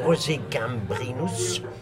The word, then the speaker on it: Rosé Gambrinus: Der fik han Rosé Gambrinus, det skal blive udtalt på fransk, og det var en helt vidunderlig oplevelse.